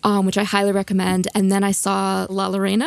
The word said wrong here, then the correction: La Llorona: La Llorona.